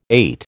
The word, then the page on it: ait: American English